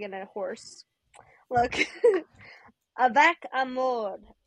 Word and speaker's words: amour: Amour. – Amour. I refuse to believe you're taking French lessons with that pronunciation.